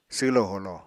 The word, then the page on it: swlo-holo: apatani